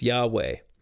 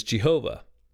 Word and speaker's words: Yahweh: And so the way this word is generally pronounced is Yahweh.